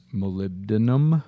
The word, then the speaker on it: molybdenum: Kind of way down on the bottom of the list here, another one is molybdenum.